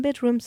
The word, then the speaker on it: BDRMM: c'était le groupe post-punk shoegaze britannique Bedroom, qui s'écrit donc BDRMM, avec le titre It's Just a Bit of Blood.